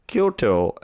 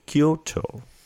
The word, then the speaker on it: Quito: Yeah, by the way, do you know that Houston player Quito is pronounced Kyoto and not Quito? When I saw a player whose name was spelled Q-U-I-T-O, I was like, Quito, that's great. And then the announcer was like, Kyoto.